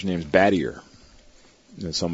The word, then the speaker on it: Battier: So all of his records got changed to Battier, B-A-T-T-I-E-R. And he met my mother in 76. They got married. And she said, it looks French. We're going to pronounce it Battier.